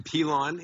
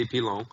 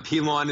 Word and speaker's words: Pilon: Pilon is the most accepted way. If you want to get kind of French-Canadian, you could say Pilon. But Pilon is the preferred over Pilon, Pilan, and all the other weird ones I get.